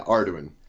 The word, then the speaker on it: Arduin: Additionally, he has authored an original fantasy series, Tales of Arduin. Am I saying that right? – Yeah, Arduin.